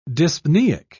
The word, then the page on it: dyspnoeic: British English